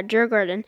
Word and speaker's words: Djurgården: Desjardins IF, if I'm pronouncing that right. – I think you're referring to the Swedish team that he played for was Djurgården. – Yeah, Djurgården.